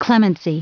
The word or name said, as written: clemency